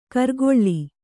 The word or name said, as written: ಕರ್ಗೊಳ್ಳಿ